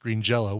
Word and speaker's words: Green Jelly: And we had Jury on, birthday boy Jury himself, to talk about Green Jello, which is spelled Green Jelly but pronounced Green Jello.